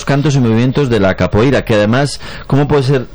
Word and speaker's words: Capoeira: Pues todos sabemos lo que es la copaíra, ¿verdad? Capoeira. Qué mal pronuncio últimamente.